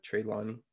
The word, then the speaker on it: Trelawney: Basically, basically, she's kind of like throwing shade very loud in class, but it's almost like Tre- Trelawney doesn't even notice. Treyway, am I saying it right? Trelawney, Trelawney.